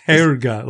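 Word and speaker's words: Härga: Yeah, I mean, I couldn't even pronounce Harga. Hargo? How did you say that? – Harga. It's Herga, like H. Think of a diphthong, air. Herga.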